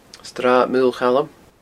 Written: srathmaolchaluim

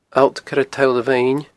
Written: Allt Coire Toll a Mheine

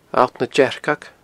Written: Allt nan Dearcag

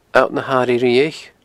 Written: Allt na h-Airighe Riabhaich

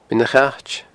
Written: Beinn a' Chait

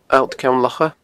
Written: Allt Ceann Locha

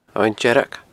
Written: Abhainn Dearg

